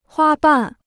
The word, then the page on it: 花瓣: huā bàn